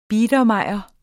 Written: biedermeier

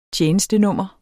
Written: tjenestenummer

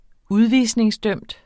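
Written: udvisningsdømt

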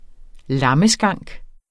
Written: lammeskank

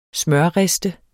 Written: smørriste

